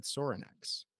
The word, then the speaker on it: Sorinex: So I want to preface again, you're the marketing and sales director at Sorinex.